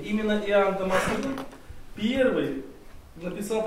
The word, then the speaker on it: Дамаскин: Иоанн, и такое имя, Иоанн Дамаскин. Иоанн Дамаскин. Или Дамаскин. Дамаскин, Дамаскин. Ну, мне кажется, православно говорят Дамаскин почему-то. Ну да, ударение на «и», наконец. Иоанн Дамаскин.